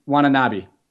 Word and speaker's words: Watanabe: I remember how to say his last name. Just give me a moment because Harris taught me. Watanabe. try to not butcher it.